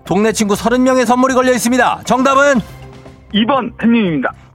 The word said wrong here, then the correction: 햄릿: 2번 뭐라고요? 햄릿이요 2번 햄님 햄릿이요 제 발음이 안 좋을 때 2번 햄릿, 햄릿.